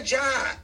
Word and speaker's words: Job: It wasn't the job. And I, you know, I got so much in me, but job in the Bible, not even pronounced job, is Job. J-O-B, the book of suffering.